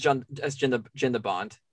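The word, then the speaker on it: Jan de Bont: Is it Jean DeBont? – It's Jean DeBont. Yeah.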